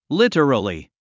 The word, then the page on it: literally: lítərəli; リタラリィ